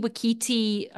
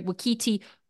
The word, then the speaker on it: Waititi: And I start to think it's like a Taiki Waititi, I've just pronounced him wrong, Waititi mockumentary.